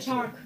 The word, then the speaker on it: Chark: DJ Clark war so. – Shark. Nee, Shark. – Nein, Shark. Shark? Von was? – Von den Jaguars. – Okay, der wird Shark ausgesprochen? Mhm. Okay. – Der ist ja CH.